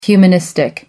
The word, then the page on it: humanistic: American English